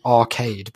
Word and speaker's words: Archaide: This was a trick I'd learned from Art 3 Mist during one of our early online pseudo-dates on a planet called Arcade, but it's spelled A-R-C-H-A-I-D-E. So it's like Archaide, which is really nice.